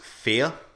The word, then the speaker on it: Fe: Yeah, according to Wikipedia, it's pronounced fear.